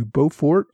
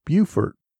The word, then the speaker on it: Beaufort: I referred to Beaufort on Port Royal in South Carolina. Alan Rosenfeld pointed out that it is pronounced Beaufort.